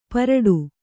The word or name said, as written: ಪರಡು